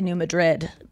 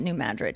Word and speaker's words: Madrid: I wanted to say New Madrid, but no, we pronounce it New Madrid here.